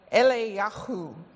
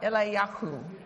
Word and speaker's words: Eliyahu: Actually, his name is a very bold name, and it's pronounced in Hebrew as Eliyahu, Eliyahu. Now, you've got to say it with a bit of spit in the throat. So try it, Eliyahu. You don't feel any phlegm coming up, you're not saying it right.